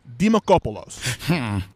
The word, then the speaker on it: Dimakopoulos: You got to hit that K hard. – Dimakopoulos? – Well, see, like, I was talking, is my last name, the way I say it properly, is Dimakopoulos. – With the Kopoulos.